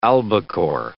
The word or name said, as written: albacore